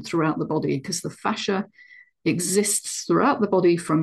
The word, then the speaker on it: fascia: So he also talked about fascia. Now, fascinating fascia, or fascia, some people pronounce it, is such a complex connective tissue structure in the body.